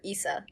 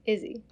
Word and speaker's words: Isa: No, it's ISA. ISI. Izzy. I listened to the audiobook. Oh. – It's a weird pronunciation. – I don't like that. I don't like that it's not spelled with like Izzy. I was pronouncing it Issa in my head.